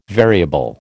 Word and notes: variable: American English

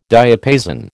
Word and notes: diapason: American English

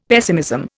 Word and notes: pessimism: Indian

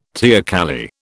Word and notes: teocalli: British English

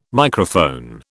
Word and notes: Microphone: British English

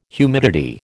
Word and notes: humidity: American English

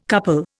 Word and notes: couple: Indian

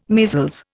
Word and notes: measles: Indian

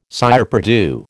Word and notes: cire perdue: American English

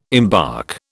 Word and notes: embark: British English

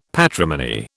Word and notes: patrimony: British English

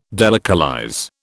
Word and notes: delocalize: British English

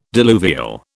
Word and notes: diluvial: British English

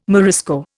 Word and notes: Morisco: Indian

